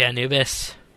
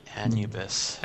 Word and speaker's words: Anubis: Like, I just always say it like that. What about you guys? – Anubis. – I know, that's how it's supposed to be said, but it just comes out that way for some reason.